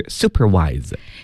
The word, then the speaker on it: supervise: 那朋友们注意啊，这个里的 vise 这个 s 呢，它要读成的是 z 这个音，所以是 supervise。